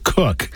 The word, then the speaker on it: Koch: Dr. Kathy Koch, it looks like Koch, but it's actually pronounced Cook, talks about relating to adult children, how to show respect, listen more, step back, and entrusting your kids into God's care.